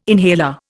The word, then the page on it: inhaler: Indian